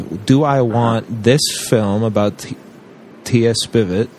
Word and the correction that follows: Spivet: and I actually believe it's pronounced Spivet.